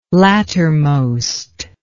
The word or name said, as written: lattermost